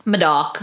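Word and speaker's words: Médoc: So the entire left bank is considered the Medoc, which is spelled M-E-D-O-C, which is often pronounced Medoc. – Medoc. That's definitely how I would say it. That's, I know, but it's pronounced Medoc.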